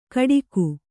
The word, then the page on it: ಕಡಿಕು: Kannada